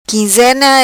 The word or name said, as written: quinzena